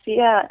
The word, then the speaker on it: Sia: The name Sia, it's pronounced Sia in Gaelic, means the number six.